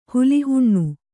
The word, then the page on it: ಹುಲಿಹುಣ್ಣು: huli huṇṇu